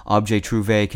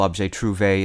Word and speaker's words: objet trouvé: By the way, the plural of objet trouvé is pronounced the same way, but it's spelled with an S added to each of the two words.